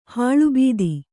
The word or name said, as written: ಹಾಲುಬತ್ತ